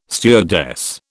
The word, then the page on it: stewardess: British English